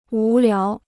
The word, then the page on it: 无聊: wú liáo